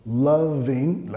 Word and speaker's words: loving: Loving, la acentuación de la palabra, primera sílaba acentuada, vas a tomar el sonido vocálico y extenderlo. Loving, la sílaba reducida, vamos a cortar, reducir el sonido vocálico ahí.